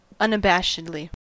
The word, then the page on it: unabashedly: American English